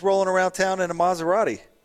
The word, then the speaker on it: Maserati: It's pronounced Maserati.